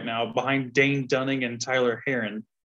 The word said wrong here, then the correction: Hearn: So, Hearn. do you even know the name Tyler Heron? I do. You're saying it wrong. Taylor Hearn.